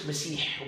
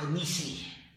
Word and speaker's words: مسيح: نعم، المسيح الدجال هو كلمة مسيح، وبعضهم نطق ميسيح بالكسر، وبعضهم قال ميسيخ. طبعا هذا بذكره بعض رواة الحديث، لكن أكثر على أنه مسيح، وقالوا لأنه ممسوح العين، ودجال من الدجال، هو مبالغ في الكلام، فهو أول مبدأه يدعي النبوة ثم يدعي الألوهية.